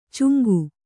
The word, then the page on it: ಚುಂಗು: cuŋgu